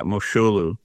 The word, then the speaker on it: Moshulu: We say Moshulu Parkway, like it's a Jewish word, but it's not. Right. How do you say Moshulu? – Moshulu. Yeah. – Moshulu. Oh, we've been saying it wrong, trying to give it away to other people.